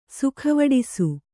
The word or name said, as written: ಸೊಗವಡಿಸು